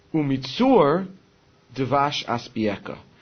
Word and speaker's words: Umitzur devash aspieka: The proper way to say it is, Umitzur devash aspieka. And from the stone, he will feed us honey.